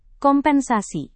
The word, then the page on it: kompensasi: indonesian